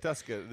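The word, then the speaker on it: Tuszka: Tuska. The Z is silent. – Derek Tuska.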